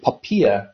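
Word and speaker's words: POPIA: Yeah, I think the regulators actually gone to great trouble to ask us to pronounce it Poppia, but I'm actually right there with you. I can't get Poppy out of my head, so we can go with Poppy Act.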